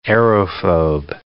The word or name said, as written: aerophobe